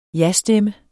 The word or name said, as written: jastemme